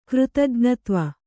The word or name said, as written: ಕೃತಜ್ಞತ್ವ